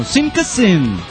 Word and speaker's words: sim que sim: Bueno, aprovecho para decirle al señor Lagarto que en portugués sí que sí se pronuncia casi igual que en castellano. Sim que sim. – Fracasado. – No es fracasado. Es sim que sim.